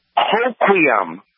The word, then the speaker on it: Hoquiam: Hoquiam. – I love that name, Hoquiam. – Yeah, H-O-Q-U-I-A-M.